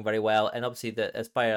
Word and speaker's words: Aspyr: Um, uh, made the uh, being worked on, developed by Aspyr. Uh, I think is how you say it. A S P Y R. I, I say Aspyr, but uh, you know, yeah, that sounds about right to me.